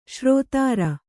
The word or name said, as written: ಷೇರುದಾರ